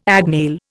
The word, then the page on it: agnail: Indian